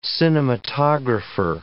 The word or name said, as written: cinematographer